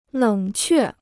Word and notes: 冷却: lěng què

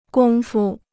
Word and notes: 工夫: gōng fu

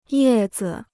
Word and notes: 叶子: yè zi

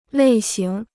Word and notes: 类型: lèi xíng

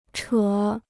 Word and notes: 扯: chě

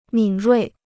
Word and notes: 敏锐: mǐn ruì

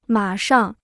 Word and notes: 马上: mǎ shàng

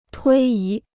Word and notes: 推移: tuī yí

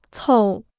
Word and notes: 凑: còu

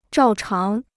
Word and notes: 照常: zhào cháng

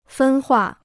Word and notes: 分化: fēn huà

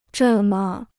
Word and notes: 这么: zhè me

